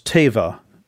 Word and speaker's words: tevah: The Hebrew word is teva, T-E-V-A-H would be pronounced.